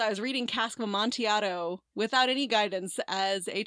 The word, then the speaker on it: Amontillado: And I really liked it, but I kept pronouncing it in my head as Amontilladiello. – Oh, no. – Cannot explain why I had these extra syllables in there.